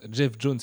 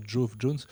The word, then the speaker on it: Geoff Johns: C'est comme on prononce Jeff Jones, c'est pas Jove Jones.